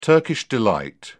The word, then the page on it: Turkish delight: British English